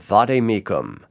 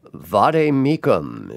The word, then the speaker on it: vade mecum: Uh, that would have gotten me in big trouble back in high school Latin, where we were taught that in classical Latin, the V is pronounced like a W, so it should be Vade Mecum. But Glenn says Vade Mecum.